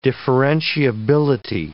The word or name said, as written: differentiability